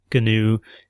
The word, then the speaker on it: GNU: You know, I've been pronouncing it GNU this whole time, where you pronounce the G.